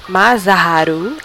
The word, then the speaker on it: Masaharu: como o Hayato Matsuo e Masaru, Masaharu, Masaharu Iwata. Agora falei certo.